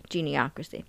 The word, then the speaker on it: Geniocracy: Their minor scriptures are called Sensual Meditation, Let's Welcome Extraterrestrials, Geniocracy, that's how you say it the second way, Geniocracy, Yes to Human Cloning.